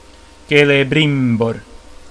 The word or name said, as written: Celebrimbor